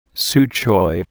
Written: su choy

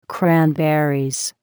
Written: cranberries

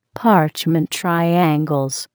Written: parchment triangles

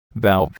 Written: valve

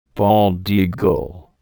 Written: bald eagle

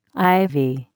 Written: ivy

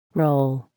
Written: roll

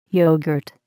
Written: yogurt

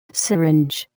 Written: syringe

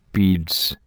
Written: beads

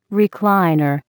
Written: recliner

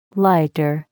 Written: lighter

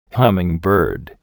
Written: hummingbird

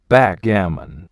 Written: backgammon

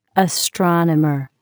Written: astronomer

